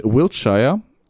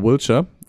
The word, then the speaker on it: Wiltshire: Es ist bei Amesbury in Wiltshire oder Wiltshire, weil das Shire wird ja nie so ausgesprochen bei denen, weil die sind komisch, in Südwestengland.